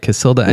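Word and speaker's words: Cacilda: Is it Casilda? – Yeah, you got it. – All right, Casilda, well, and then her last name, Jetta.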